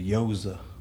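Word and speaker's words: gyoza: The goiza was on point. – The yoza. – Yoza? – Yeah. – Who? – Yoza. – Yoza? – Yeah, that's how you pronounce it.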